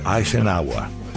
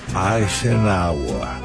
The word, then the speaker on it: Eisenhower: Se pronuncia Eisenhower. Eisenhower. – Como iba diciendo, Eisenhower. Ay, sin agua.